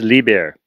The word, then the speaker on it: Liebherr: It's Liebherr.